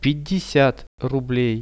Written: 50 rublei

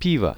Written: pivo

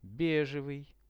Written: bege

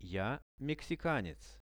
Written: ja mexikanec

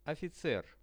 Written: oficer

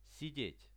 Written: sidet

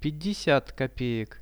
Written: 50 kopeek

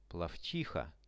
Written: plovchiha

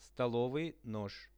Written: stolovy nozh

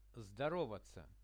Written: zdorovatsia

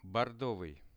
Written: bordovyi

